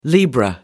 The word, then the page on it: libra: American English